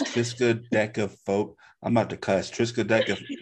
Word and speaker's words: triskaidekaphobia: She said it right. Triskaidekaphobia. – She didn't know. You said deck.